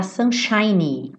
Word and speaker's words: sunshiny: Não esquece desse Y, tá? Sunshiny. Porque ele tá fazendo adjetivo, lembra que eu falei pra você? Sunshine, termina com E e o E é mudo, seria o brilho do sol, tá? E sunshiny está fazendo adjetivo. Então, esse Y tem que aparecer.